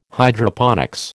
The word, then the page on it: Hydroponics: American English